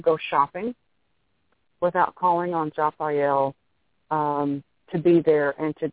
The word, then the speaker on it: Jophiel: And I wanted to introduce one of the archangels for you, and her name is Japhiel, and that is spelled J-O-P-H-I-E-L.